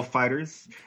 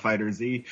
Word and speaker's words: FighterZ: Number two for the month was Dragon Ball FighterZ, which Namco went out of their way to say that's how it's pronounced, not FighterZ. It is Dragon Ball FighterZ.